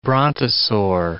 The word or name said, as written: brontosaur